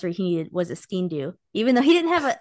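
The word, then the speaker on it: sgian-dubh: Now, the difference, I think you said it right, but just for the listeners, the way you pronounce it's basically skindo means black knife. The way to remember it is like, say like skiing, but skin do. So you're kind of pronouncing the SG ski in, do,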